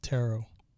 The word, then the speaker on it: tarot: Tarot is pronounced tarot. Tarot reading. I think I've heard that. I just don't know too much about it. Like Sparrow. Tarot Sparrow.